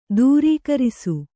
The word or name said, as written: ದೂರೀಕರಿಸು